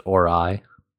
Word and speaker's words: Ori: It is pronounced Ori.